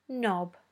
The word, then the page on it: knob: British English